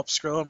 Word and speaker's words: Pescrilo: You can find me on Twitter at Paul Pescrillo. My last name is spelled P-E-S-C-R-I-L-O.